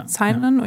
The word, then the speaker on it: Sinon: Also von Elliot Page spielt ja Sinon, meine ich? – Ja. – Sinon, ja. Wie es im Englischen ausgesprochen wird.